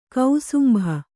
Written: ಕುಸುಂಬಾ

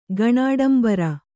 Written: ಗಣಾಡಂಬರ

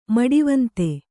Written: ಮಡಿವಂತೆ

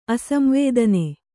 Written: ಅಸಂವೇದನೆ

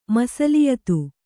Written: ಮಸಲಿಯತು

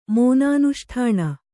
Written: ಮೋನಾನುಷ್ಠಾಣ

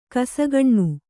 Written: ಘಸಕ್ಕನೆ

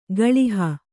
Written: ಗಳುಹು

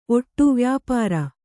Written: ಓಡ

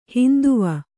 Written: ಹೈಂದವ

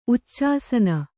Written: ಉಚ್ಚಾಸನ

